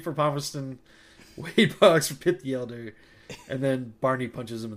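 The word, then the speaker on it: Pitt the Elder: what I mostly phonetically remember, Hit the Elder. – Super close. Enough? So I'll give it to you. – Yeah. – So it's Pit the Elder.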